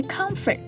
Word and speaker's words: comfort: 最后一个要注意的重点是 comfort， comfort 中的 or 要读成 er 的音。